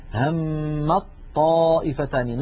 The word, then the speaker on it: همت طائفتان: لاحظوا لا أنطق التاء أبدا، همت طائفتان، ننطق طاء مشددة تحولت التاء إلى طاء ثم أدغمت الطاء في الطاء فصار النطق بطاء مشددة، هذا كله يا إخوتي في حال الوصل.